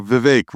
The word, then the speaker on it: Vivek: You know, Vivek rhymes with fruitcake.